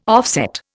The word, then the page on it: offset: Indian